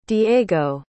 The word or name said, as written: diego